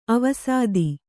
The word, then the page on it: ಆವಸಿತ: kannada